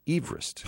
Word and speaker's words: Everest: But he didn't pronounce it Everest. He and his family pronounced Everest Everest. – Huh. I'll be darned. – Two syllables. – He's a Welshman? – Everest. Welsh, yeah. That's how it's supposed to be pronounced.